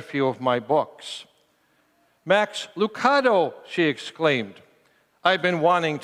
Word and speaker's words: Lucado: Is it Lucado or Lucado? For the record, he says, we say Lucado.